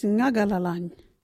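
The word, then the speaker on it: Ngagalalany: Our local Wadjuri word is Ngagalalan.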